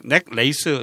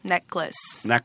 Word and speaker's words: necklace: 넥클래스인데 이게 넥레이스 이렇게 말을 하시면 진짜 많아요. 음. 목걸이 넥레이스 아니에요. 네. 어떻게 발음한다고요? – 넥클래스. – 넥클래스 이렇게 발음을 합니다.